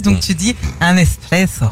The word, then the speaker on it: espresso: Espresso. – Espresso, ouais. Étymologiquement, on ne prononce pas le X. L'origine du mot vient de l'italien esprimere.